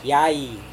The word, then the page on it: yai: serewen